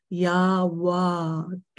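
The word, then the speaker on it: Ya Wadud: Emphasis on the D at the end, Yawadud.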